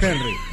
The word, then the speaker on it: Henry: O sea, nosotros Henry. – Le decimos Henry. – La H muda.